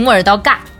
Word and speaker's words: 莫尔道嘎: 那个除了这个森林本身，那这个城市呢，在这边坐落的这个莫尔道嘎，它应该怎么念来着？ – 我们当地人就习惯性莫尔道尬。